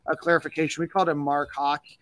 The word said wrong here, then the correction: Hocke: Uh, it is actually Mark Hocke, which immediately makes him uh, my favorite uh, player uh, on the, our favorite coach on the coaching staff.